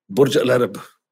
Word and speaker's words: Burj Al Arab: Burj Al Arab.